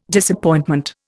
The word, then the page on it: Disappointment: Indian